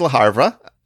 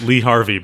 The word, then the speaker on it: Le Havre: Number 43 is Le Havre. Help me out here. – Let's go with that. I was going to pronounce it Lee Harvey, but that's not correct.